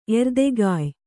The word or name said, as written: ಎರ್ದೆಗಾಯ್